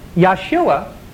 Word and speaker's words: Yeshua: Now, the name Yeshua, is the Hebrew pronunciation of our Savior.